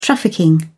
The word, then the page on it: trafficking: British English